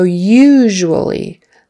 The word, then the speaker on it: usually: So usually, that has a long U in there twice. Usually, usually. And in between those two long U's, there's a zh sound, zh. Zh is just the SH sound with your voice on.